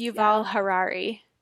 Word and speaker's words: Yuval Harari: I think it's Yuval Harari.